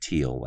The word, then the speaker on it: Thiel: So if you look, it's Peter, I think it's spelled T-H-I-E-L, but it's pronounced, I think, Thiel, like T-E-A-L. – Correct.